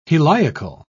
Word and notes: heliacal: British English

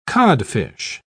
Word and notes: codfish: British English

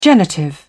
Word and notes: genitive: British English

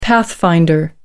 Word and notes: pathfinder: American English

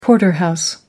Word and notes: porterhouse: British English

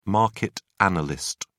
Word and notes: market analyst: British English

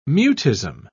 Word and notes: mutism: British English

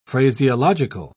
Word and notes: phraseological: British English

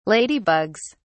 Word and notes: ladybugs: British English

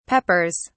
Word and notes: peppers: British English

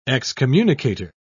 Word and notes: excommunicator: British English

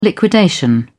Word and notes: liquidation: British English

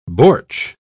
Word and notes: bortsch: British English

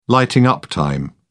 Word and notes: lighting up time: British English

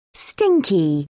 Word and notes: stinky: British English

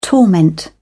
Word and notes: torment: British English

